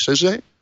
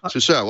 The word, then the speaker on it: SUSE: It's SUSE? SUSE? – SUSE. – Sousa. Well, I've been saying that wrong for years.